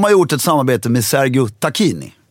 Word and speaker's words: Sergio Tacchini: Vad sa du? – Sergio Tacchini. – Nej. – Jo. Korrekta uttalet, jag har fått nu höra det av VD:n för Triva. Jag har själv googlat det och kollat på YouTube.